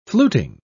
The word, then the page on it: fluting: British English